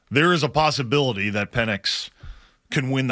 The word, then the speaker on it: Penix: I believe it's pronounced Penix.